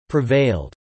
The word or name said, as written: prevailed